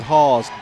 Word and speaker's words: Haws: Make that Hawes, Delaney Hawes. Her number was different as well. Rebound, Hawes. That's spelled H-A-W-S, like pause.